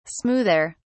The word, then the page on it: smoother: British English